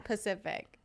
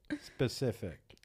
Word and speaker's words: specific: People who say specific like Pacific. – Well, how are you supposed to say it? How do you say it? – Pacific, specific. – People who are trying to say specific, and they say Pacific.